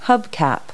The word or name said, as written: hubcap